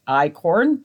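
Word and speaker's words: einkorn: And eichhorn, it's pronounced like eichorn, but it's E-I-N-K-O-R-N. That's how it's spelled.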